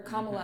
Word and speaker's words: Kamala: Or Kamala, mm-hmm.